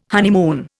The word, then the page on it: honeymoon: Indian